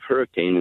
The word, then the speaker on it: Hurricane: One, I think if you go to the town of Hurricane in southern Utah, most people in southern Utah pronounce it hurricane. Mm-hmm. And people snicker and think that's some sort of a mispronunciation. No, it's actually just the British pronunciation of the word.